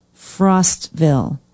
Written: Frostville

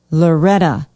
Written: loretta

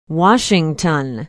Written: washington

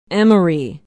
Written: emery

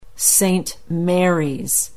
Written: Saint Marys

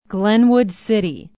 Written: glenwood city

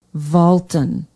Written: valton

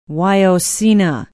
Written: wyocena

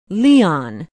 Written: Leon

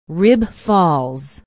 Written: rib falls

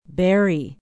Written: berry

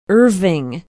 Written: irving

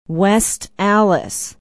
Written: West Allis

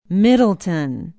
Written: Middleton

